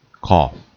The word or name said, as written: cough